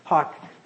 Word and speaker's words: hough: That's what hawk the horse, hawk, you might pronounce that ho, but it's I or hue, it's actually hawk. Believe it or not, H-O-U-G-H, or H-O-U-G-H, I think.